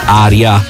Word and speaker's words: ARIA: praticamente Tame Impala, che nel corso della sua carriera ha ottenuto nomination ai Grammy, vinto gli ARIA, ecco così, ARIA, lo pronunciamo nella sua lingua madre, Awards, e ha collaborato con artisti come Rihanna, Travis Scott, The Weeknd, diventando un riferimento per la psichedelia moderna.